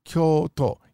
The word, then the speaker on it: Kyoto: So in America, we tend to say Kyoto. Oh, Kyoto is so beautiful. I just love Kyoto. But that's not how they say it in Japan. It's Kyoto. You must lengthen it. Kyoto.